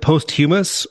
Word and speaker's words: posthumous: Is posthumously? – Is it posthumous or posthumous? – Oh, I would say posthumous.